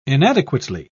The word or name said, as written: inadequately